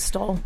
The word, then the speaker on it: Stoll: This one's for Stoll? How do you pronounce that? S-T-O-L-L, whatever it is. – I'd say Stoll. – Stoll, it's a German company.